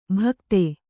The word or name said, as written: ಭಕ್ತೆ